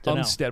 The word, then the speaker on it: Umstead: Umstead, am I saying it right? – Yeah.